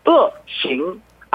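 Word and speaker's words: 어싱 (恶行): 발음은 사성하고 이성을 써갖고 어싱 악행 어싱이었습니다.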